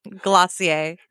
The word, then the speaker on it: Glossier: Okay, so you have written a book about the beauty industry in general, and now help me pronounce this company's name. – Glossier. – Glossier. – Yeah, a little bit fake French.